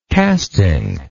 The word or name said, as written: casting